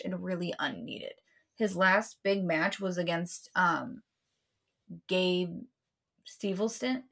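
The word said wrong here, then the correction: Gable Steveson: Yeah, I can't say his name correctly. – Gabe Stevenson.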